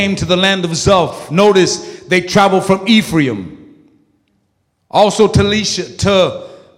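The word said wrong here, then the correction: Shalishah: I'm going to say it right. Shalisha? Let me say it right. Shalisha.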